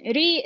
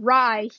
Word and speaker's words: Ri: His name is spelled R I, pronounced Ri, not Rai. He is not a loaf of bread.